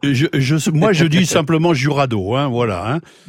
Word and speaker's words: Jurado: On dit Jurado, J-U-R-A-D-O, Jurado.